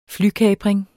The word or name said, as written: flykapring